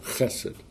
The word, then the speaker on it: Chesed: It's kind of guttural. Chesed. H-E-S-E-D.